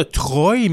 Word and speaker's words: Troyes: Et puis Troie euh, s'appelle T-R-O-I-E. Oui. Tandis que là, Chevalier de Troie, c'est T-R-O-Y-E-S. Alors, on pourrait dire de Troie, mais euh, c'est. – on le, prononce quand même Troie.